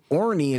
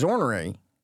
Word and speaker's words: Orny: Oh, so Orny is ornery.